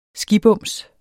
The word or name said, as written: skibums